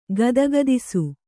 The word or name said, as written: ಗದಗದಿಸು